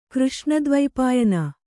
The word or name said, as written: ಕೃಷ್ಣದ್ವೈಪಾಯನ